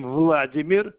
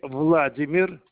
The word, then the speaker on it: Vladimir: My name Vladimir Opendik. – Okay, sir. Can you spell your name, please? – My name Vladimir.